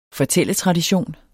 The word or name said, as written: fortælletradition